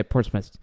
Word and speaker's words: Portsmouth: I told him like, "Oh, this is my friend from England," and then he's and then I said he lives in Portsmouth, and then he's like, "No, no, no, that's not how you say it. You say Portsmouth," and I'm I'm still saying it bad, but he corrected me like you guys do all the time.